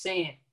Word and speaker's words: 5AN: Yes, it's crazy because my name is pronounced San. – San, okay. I was wondering, yeah. I should ask you. – Tupac got T-U, and uh-huh. Then he got So it's the 5 is an S.